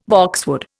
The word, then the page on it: boxwood: Indian